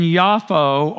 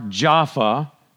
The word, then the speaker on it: Jaffa: And in Yafo, or Jaffa, is how it's pronounced currently, there was a certain taught one named Tabitha, which means Dorcas.